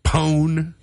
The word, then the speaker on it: pwn: Pwn. Mm. Like... P-W-N, when it's pronounced like own, pwn, I totally pwned or pawned my opponent in an epic game of tag yesterday.